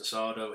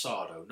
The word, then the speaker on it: Sardo: Somebody would address him as Mr. Sardo and he'd get annoyed and say, no, that's Sardo, no Mr. Accent on the dough.